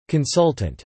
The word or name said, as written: consultant